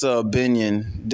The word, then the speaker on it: Gbenyon: It's Gbenyon. The G is silent. It's G-B-E-N-Y-O-N, but yeah, we don't say the G.